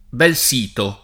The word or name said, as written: Belsito